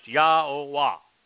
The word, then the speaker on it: Yahweh: Yahweh, God's one and only name, pronounced Yahweh, based upon Hayah, to exist,